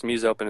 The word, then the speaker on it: MuseOpen: MuseOpen, or how do I pronounce it? – You know, domains are hard to come by, so anything works. MuseOpen is what I go by.